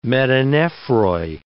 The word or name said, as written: metanephroi